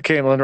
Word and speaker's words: Caemlyn: And then we mentioned uh, the Caemlyn Road. Is that how you pronounce it? – Yeah, the Caemlyn Road.